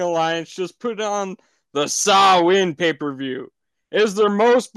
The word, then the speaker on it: Samhain: And although it is spelled differently, it is pronounced Samhain.